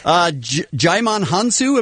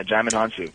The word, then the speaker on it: Jaimon Hansu: Jaimon Hansu, am I saying that right? – Yeah, you did. Wow, you're like the only person who's actually said that correctly. Jaimon. Yeah, Jaimon Hansu.